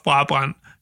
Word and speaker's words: Brabrand: I was 11 years old and a few years into playing soccer at my local club in the Aarhus suburb of Brabant, spelled like Brabrand, though it has nothing to do with the makes of brassieres.